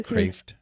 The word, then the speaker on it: Kreeft: His last name is spelled Kreeft. Looks like Kreeft, but he pronounces it Kraft.